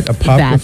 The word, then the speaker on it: Apopka: Apopka, Florida? – That sounds perfect to me.